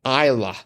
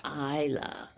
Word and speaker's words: Isla: The actress Isla Fisher pronounces her name Isla. – Isla.